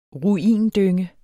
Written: ruindynge

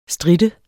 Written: strit

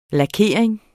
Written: lakering

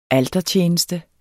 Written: altertjeneste

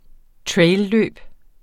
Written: trailløb

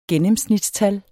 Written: gennemsnitstal

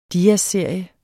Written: DIASSERIE

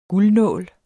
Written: guldnål

